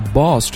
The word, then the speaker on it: boss: 同时我们要注意一下 boss 这个词的发音，在美式发音当中啊，它的口型也不是那么大的，是圆的，它跟热的那个 hot 那个啊的音是不一样的，它是 oh boss boss people around.